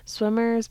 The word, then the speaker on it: SWMRS: The first, we're going to talk about Dayglow, then a band called Swimmers, but it's spelled S-W-M-R-S, like missing the vowels.